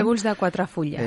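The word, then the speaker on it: Trèbols de quatre fulles: Se titula eh, de, es una serie, Trebles de, Cuatro Fulls no sé si lo he pronunciado bien en catalán. Trébols de Cuatro Fullas.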